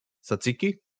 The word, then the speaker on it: tzatziki: And then also, how do you pronounce the that Greek yogurt and cucumber and dill sauce? – Satsini. – Tzatziki? – Oh yeah, sorry, tzatziki.